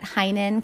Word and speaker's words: Heynen: You can find me on Instagram at Heinen Counseling and Coaching. Name is spelled H-E-Y-N-E-N.